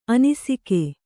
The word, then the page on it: ಅನಿಸಿಕೆ: anisike